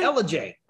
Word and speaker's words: Ellijay: I'm at the most beautiful RV resort we've been to, Tolona Ridge RV Resort, located in, how do you say, is it LJ? – LJ.